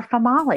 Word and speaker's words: Famali: I want to name her Famali. – Family. – Female. – Oh, female. Okay. Famali. – Female. – Rhymes with tamale.